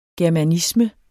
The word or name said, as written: germanisme